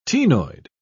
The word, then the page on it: ctenoid: British English